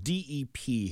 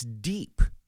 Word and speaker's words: Dep: How would you pronounce that? – The dep? – No, it's deep. – It's pronounced deep. And for my entire existence, seeing that product, using that product when I was a kid, when I actually had hair to use it with, I always thought it was dep. But it is, in fact, pronounced deep because the E has the long thing over the top of it.